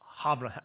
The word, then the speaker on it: Havre: It was a steamship called the Ville de Havre. Help me with this. If you, how do you pronounce H-A-V-R-E? Havre, all right?